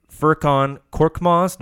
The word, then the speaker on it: Furkan Korkmaz: They re-signed Furkan Korkmaz. Did I pronounce that right? – Yep, that's it.